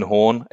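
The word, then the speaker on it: Horne: My name is Ian Horn. Again, the E is silent at the end of Horn.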